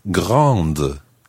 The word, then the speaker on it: grande: Now, in the feminine form, the word grand becomes grande, and you pronounce the D because there's an E on the end. Grande.